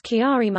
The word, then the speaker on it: Chiari: Chiari. – I apologize to anybody who has that because I still don't know how to say it. – Fact check. The correct pronunciation is Chiari malformation.